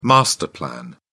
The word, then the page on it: master plan: British English